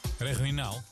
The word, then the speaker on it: regionaal: Een regoniaal... Nee hoor, hoe spreek je dat uit? – Regionaal. – Heel goed.